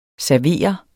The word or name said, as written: SERVEREDES